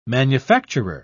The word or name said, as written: manufacturer